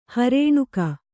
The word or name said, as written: ಹೊರನೂಕು